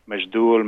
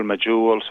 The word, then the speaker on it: Majhul: So you see different pronunciation or spelling of it, Majdul, Majhul, something, but it comes from the Arabic word Majhul.